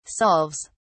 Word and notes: solves: British English

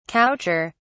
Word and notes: coucher: British English

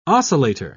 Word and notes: oscillator: British English

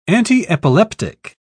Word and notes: antiepileptic: British English

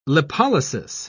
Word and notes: lipolysis: British English

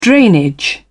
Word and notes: drainage: British English

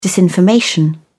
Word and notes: disinformation: British English

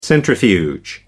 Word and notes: centrifuge: American English

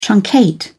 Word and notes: truncate: British English

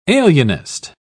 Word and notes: alienist: British English

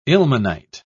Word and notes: ilmenite: British English

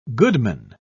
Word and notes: goodman: British English

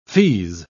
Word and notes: feaze: British English

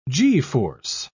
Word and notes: g-force: British English

